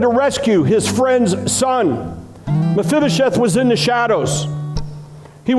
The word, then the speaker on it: Mephibosheth: It was like Meshibasheth. How do you say that? Uh, Say it. Mephibosheth. Fibba, Mephibosheth.